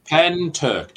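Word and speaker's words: Pentyrch: Getting back to the Pentesh, how do you pronounce that? – Pen Turk. Just say Pen and Turk, you know, like Turkey.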